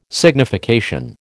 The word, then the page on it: signification: American English